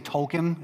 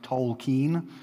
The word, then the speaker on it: Tolkien: So The Lord of the Rings, The Hobbit, written by Tolkien, and it is pronounced, a lot of people say Tolkien, it's actually pronounced Tolkien, which I recently learned, so I was making that same mistake as well, Tolkien.